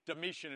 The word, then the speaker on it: Domitian: Domitian is how it's pronounced.